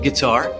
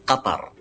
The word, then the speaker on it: Qatar: Americans like to pronounce it as sometimes guitar. – Excellent! – I've also heard gutter, but there's definitely no cutter.